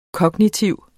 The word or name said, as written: kognitiv